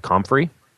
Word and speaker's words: comfrey: Top two things would be one that's a little bit harder to find called comfrey. It's spelled C-O-M-F-R-E-Y.